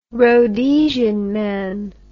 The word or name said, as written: Rhodesian man